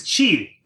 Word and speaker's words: qi: Yeah, so the old spelling, C-H-I, and then the new spelling is QI. Both of these are supposed to be pronounced the same. Even though the spellings look really different in English, it's just chi.